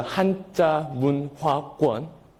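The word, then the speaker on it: Hanja Mun Hwa Kwon (한자문화권): These letters are pronounced as a Hanja Mun Hwa Kwon, but this Japanese also pronounced as Kanji Bun Ka Ken. Kind of similar, right?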